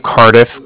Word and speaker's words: Cardiff: Can you let him know that from the Bank of Cardiff called? And Cardiff is spelled C-A-R-D-I-F-F, as in Frank Frank, so it's like car and then different.